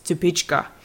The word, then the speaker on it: Stupice: It's spelled Stupis, S-T-U-P-I-C-E, but it's actually from the Czech Republic, so it's pronounced Stupička. So for more ideas on tomatoes, check out our latest newsletter where we've posted tips on how to plant your tomatoes for success.